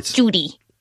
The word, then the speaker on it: duty: Judy. Do you see what I mean? – Yeah, totally see what you mean. So he was saying Judy, meaning duty, because it's the American pronunciation.